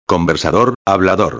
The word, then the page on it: conversador hablador: espanhol